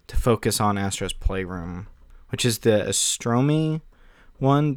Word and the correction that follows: Asobi: Did I say that right? Asobi, there it is.